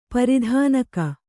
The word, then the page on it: ಪ್ರಧಾನಿಕೆ: pradhānike